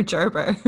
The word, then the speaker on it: Gerber: We had to Google, is it Gerber or Gerber? – Yeah, so we're going with Gerber.